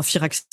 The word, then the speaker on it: Firaxis: Donc, je pense qu'ils ont énormément, Fireaxis a énormément à jouer sur, enfin Firaxis, je le prononce toujours très mal,